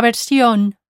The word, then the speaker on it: versión: Notice how I pronounce the S-I-O-N in words like conclusión, confusión, decisión, excursión, explosión, televisión, versión. Avoid making the English shun sound.